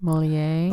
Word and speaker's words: Molière: Molière.